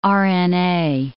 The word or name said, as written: RNA